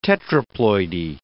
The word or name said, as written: tetraploidy